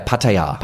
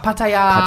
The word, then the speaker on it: Pattaya: Yeah, Pattaya. – Pattaya.